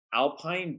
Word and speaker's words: Alpyn: And then I use a moisturizer after I come out of the shower, and it's called Alpine Beauty, but it's spelled Alpyn.